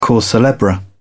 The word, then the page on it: cause celebre: British English